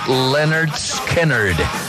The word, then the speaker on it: Lynyrd Skynyrd: El álbum se pronuncia Lynyrd Skynyrd. Y tiene la pronunciación fonética para que la gente aprendiera a pronunciar el nombre de la banda.